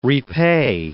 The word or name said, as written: repay